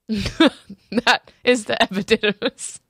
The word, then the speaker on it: epididymis: You locate the, I don't even know how to say this, but epididymis? – Epidermis? – No, that's your skin. – Mm-hmm. – Epididymis, it has an extra did, it has a did in there.